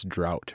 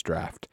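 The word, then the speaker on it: draught: And I know this is how it's pronounced, and I still inadvertently called it Guinness Drought rather than Guinness Draft. Because on every Guinness, it's D-R-A-U-G-H-T.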